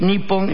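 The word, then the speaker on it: Nippon (日本): Japón en japonés se escribe en japonés igual que en chino, se pronuncia Rpon en chino y Nippon en japonés.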